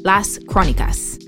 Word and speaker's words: Las Crónicas: We're calling these in-between episodes the Chronicles, Las Crónicas. – Perfect pronunciation.